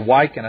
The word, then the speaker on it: Wiccan: One of the precepts of Wiccan, W-I-C-C-A-N, religion, which is the witch religion, Wiccan, or Wiccan, I think it's pronounced Wiccan, religion, is there is no good or evil.